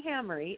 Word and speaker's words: Hamre: So, listeners, let me tell you, Dorothy Hamery is her name. Her last name is spelled Hamre, and you could find her book, The Lemonade Was Great, on Amazon, and it's full of recipes, too, family favorite recipes.